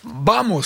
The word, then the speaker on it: vamos: Vamos, ça se prononce comme ça. – Tout à fait. – Le V se prononce B en espagnol, je ne parle pas espagnol.